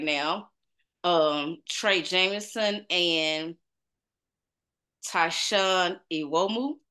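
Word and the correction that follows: Evbuomwan: Yeah, that was close. That was pretty close. Yeah, it's Awoma. Mm-hmm.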